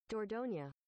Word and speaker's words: Dordogne: Dordogne? How the fuck do you pronounce that? Hold on. – Ask Google. – It's French, according to YouTube. – Dordogne.